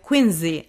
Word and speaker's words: Quincy: Quincy, actually. That's the right pronunciation.